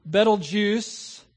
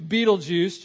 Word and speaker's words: Betelgeuse: It is called Betelgeuse or Betelgeuse or Betelgeuse. Pick your pronunciation. I'll choose Betelgeuse just because it's cool.